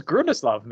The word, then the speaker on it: Grundislav: So you are actually uh, from uh, I don't know how to pronounce it. Gundaslav Games. – Gundaslav. – Grundislav. – Grundislav Games. Okay.